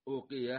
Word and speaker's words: أوقية: المفردات: الأواق جمع أوقية، يقال أوقية بضم الهمزة أوقية وكسر القاف وتشديد الياء أوقية، آخرها تاء مربوطة، وكثير من الناس ينطقها بدون همزة، يعني يقال أوقية أوقية، وبالأخص العامية بالأخص العامية أي من الناس.